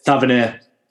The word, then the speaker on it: Tavernier: His name is James Tavernier. I'm not sure how you pronounce it. – Tavernier.